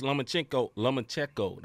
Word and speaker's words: Lomachenko: Haney defeats Lomachenko.